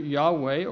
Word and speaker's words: Yahweh: The word Lord is the word which we often hear sometimes pronounced as it should be in the Hebrew, Yahweh, or some people pronounce it because they are Jewish and they don't want to say the word, so they'll say Adonai.